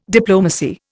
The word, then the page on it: diplomacy: Indian